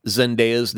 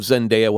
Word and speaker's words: Zendaya: Okay, so maybe Theatrical Forever pointed out that I had mispronounced Zendaya's name and said, by mistake and force of habit, Zendaya.